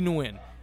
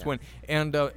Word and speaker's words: Nguyen: Kai Kamara, Lee Nguyen. I could keep going. – Nguyen. – Did I say Nguyen? – Yeah, you said Nguyen. – I know how to pronounce names. – It's Nguyen.